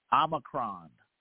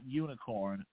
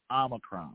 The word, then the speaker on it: Omicron: It's Omicron. Not unicorn, Omicron. And I think I know who pronounced that wrong, too.